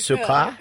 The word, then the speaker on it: Sarkar: So how do you pronounce it in French? – Sarkar. – Like sugar, okay.